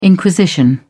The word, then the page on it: inquisition: British English